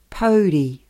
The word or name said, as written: pouri